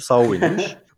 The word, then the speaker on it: Samhain: Bu arada doktorluğun bir sonu Samhain diye okuyan onun okunuşu Sauvin'miş.